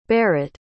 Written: berit

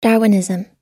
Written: darwinism

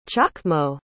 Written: chochmo

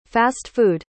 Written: fast-food

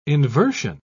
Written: inversion

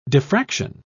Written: diffraction

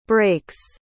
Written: brakes

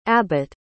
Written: Abbott

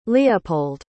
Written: leopold

